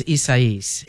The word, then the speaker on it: Isaias: We, you know, I've heard it pronounced a bunch of different ways, but the most recent was Isaias.